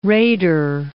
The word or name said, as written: raider